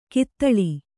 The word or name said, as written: ಘಾತಾಳಿ